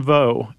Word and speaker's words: Veaux: Franklin Vo is how I'm pronouncing his last name. Vo. V-E-A-U-X.